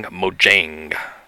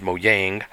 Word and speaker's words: Mojang: And I looked up a couple of the different pronunciations, and it sounds like Notch basically said that that's the best way to say it in English. I guess in Swedish you would say it as Mojang, but we'll just go with the English pronunciation for now.